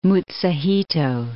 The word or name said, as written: Mutsuhito